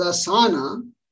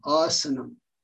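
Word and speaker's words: āsanam: If you pronounce this as asana, it means nothing. That's not a Sanskrit word at all. Okay. So sthira sukham asanam.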